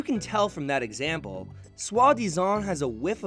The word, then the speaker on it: soi-disant: It's soi-disant. That's spelled S-O-I hyphen D-I-S-A-N-T.